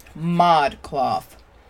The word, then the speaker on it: ModCloth: They're called Mod Cloth. I was about to say Mod Clothed, and then I was like, that don't make no sense.